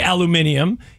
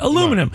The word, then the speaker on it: aluminum: You don't say aluminium. Here, we call it aluminum. – I understand that's how they pronounce it over there. That's not how we pronounce it.